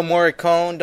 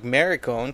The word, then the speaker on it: Morricone: Prior to that, we had Fistful of Dollars by Ennio Morricone. Don't mistake that or mispronounce that like Maricone, because that means something completely different.